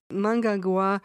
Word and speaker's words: Mnangagwa: Uh, I'm not sure I pronounce his name correctly. Mangagawa. Mangagawa. It's close. Mnangagwa.